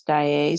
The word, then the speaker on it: Daiei: I think the pronunciation is Daiei Studios, but yeah.